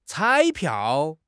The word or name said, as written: 彩票